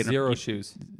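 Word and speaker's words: Xero Shoes: They make these shoes that feel like they zero shoes. – Zero shoes. Yeah, with like an X, so it's pronounced like that.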